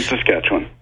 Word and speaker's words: Saskatchewan: Saskatchewan? Sass and Sasquatch? What? Okay. I just go the other way. That's all I know. – Saskatoon, Saskatchewan.